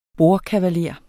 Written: bordkavaler

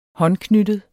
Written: håndknyttet

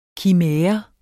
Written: kimære

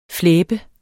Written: flæbe

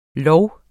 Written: lov

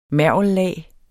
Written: mergellag

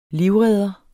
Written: livredder

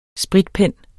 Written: spritpen

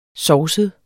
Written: sovset

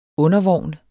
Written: undervogn

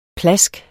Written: PLASK

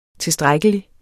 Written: tilstrækkelig

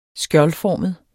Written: skjoldformet

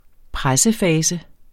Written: pressefase

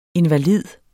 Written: invalid

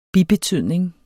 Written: bibetydning

